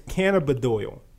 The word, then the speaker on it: cannabidiol: CBD is cannabinoid. Oh, wait, I said it wrong. I want my $5 now. It is cannabidiol.